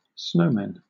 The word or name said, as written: snowmen